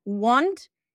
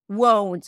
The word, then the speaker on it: won't: Won't, right? I'm not pronouncing it as want, it's won't.